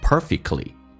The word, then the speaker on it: perfectly: T 跟 L 在一起的时候在口语化当中 T 通常不发音不会发成 perfectly 叫做 perfectly